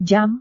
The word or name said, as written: jam